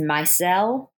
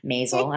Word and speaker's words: Maisel: Marvelous Mrs. Maisel. – Is that how you pronounce it? – Mm-hmm. – You would know. – You heard it here first. No, Maisel.